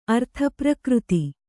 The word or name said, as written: ಅರ್ಥಪ್ರಕೃತಿ